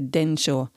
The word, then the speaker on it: Densho: que se dice Densho. Se escribe escribe D-E-N-S-H-O org.